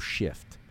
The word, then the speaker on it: SH/FT: But they spell it SH and then like a hash mark or whatever you call that. – Like a slash. – There you go. SH/FT. So Shift.